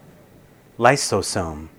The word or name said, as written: lysosome